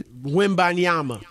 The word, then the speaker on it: Wembanyama: Wimbanyama.